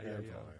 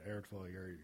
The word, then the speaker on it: Erin Foley: Am I saying it right, Erin Foley? – Erin Foley, yeah, Erin Foley. Yeah. – All right, Erin Foley, you're a very kind person.